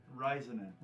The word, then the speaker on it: Raisanen: Raisanen.